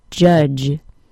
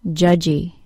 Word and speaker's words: judge: So the word judge gets pronounced judgy.